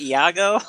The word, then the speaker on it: Iago: Fucking Iago. – Is that how you say it? Iago? – Yeah.